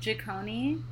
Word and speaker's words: Jikoni: It's Jikoni. – Jikoni. – It's Swahili. So, like, it's just, like, Swahili is, like, vowel consonant.